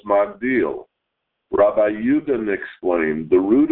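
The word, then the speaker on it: Magdil (מגדיל): Nevertheless, in our verse, it is pronounced Magdil, while in Samuel, it is changed altogether and pronounced Migdol.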